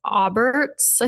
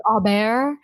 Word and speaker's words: Aubert: So the book is called Agnes Auberts, I think is how you pronounce it, or it's French. – Aubert. – Agnes Aubert. – Aubert. – Audiobook early, I got you. – Yeah, I listened to the audiobook too, and the audiobook has strong French pronunciation, and I was like, oh, okay.